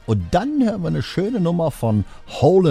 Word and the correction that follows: Hall: and Oates oder auch richtig ausgesprochen Daryl Hall und John Oates und Dream Time.